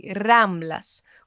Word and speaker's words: Ramblas: Mas vamos lá, você percebeu como eu pronunciei Ramblas com R bem tremido e vibrado? Isso sempre vai acontecer no espanhol quando uma palavra começa com R.